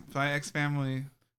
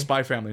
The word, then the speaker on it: Spy x Family: Just Spy Family. No, it's a silent. Okay, so Silent X.